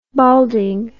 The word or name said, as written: balding